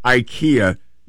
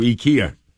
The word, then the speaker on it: IKEA: They're saying you don't pronounce it IKEA, you pronounce it IKEA. IKEA. That's the Swedish way to pronounce it.